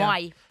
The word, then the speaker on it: Moai: Moai.